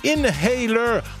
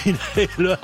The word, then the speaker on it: Inhaler: Allez, on écoute Inhaler, My Honest Face, parce qu'on ne prononce pas le H de Inhaler.